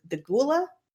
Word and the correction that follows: Goa'uld: the Gould, the Goa'uld, the Gould.